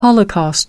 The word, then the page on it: holocaust: American English